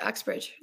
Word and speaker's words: Uxbridge: So is this in your small town of, how do you say it, Uxbridge, Ontario? – Yeah, nailed it, Uxbridge, yeah.